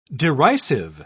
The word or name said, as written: derisive